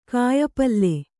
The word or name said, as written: ಕಾಯಿಪಲ್ಲೆ